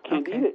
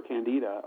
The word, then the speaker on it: Candida: Candida is a pathogen. There is no place for Candida, or Candida is the correct pronunciation, but everybody says Candida, but doctors don't like Candida. They like Candida.